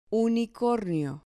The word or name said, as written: unicornio